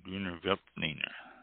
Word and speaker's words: Grüner Veltliner: G-R-U-N-E-R, Grüner, and V-E-L-T-L-I-N-E-R, Veltliner. Grüner Veltliner. And they show felt here, and I've always not pronounced it with an F, but a V.